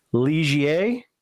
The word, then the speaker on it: Ligier: The new LMP3, it's pronounced. His take is, and we'll we'll get, take his word for it, Ligier or Ligier. So however you want to let that roll off the tongue, uh, Liger, you know, for us laymen.